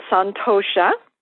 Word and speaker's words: Santosha: I hope I do not butcher the principle's name, but it's Santosha? – It's called Santosha, and it is one of the foundational principles of yoga philosophy.